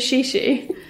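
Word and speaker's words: chichi: It's so funny because also because it's Notting Hill, everyone in there is like in chi chi chi. – Do you mean shishi?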